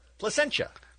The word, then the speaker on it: Placentia: Did he say she was from Placentia? – Placentia. – Placentia. Okay. – Did I say it wrong? – No, I had never heard of that town. Sound like Placenta.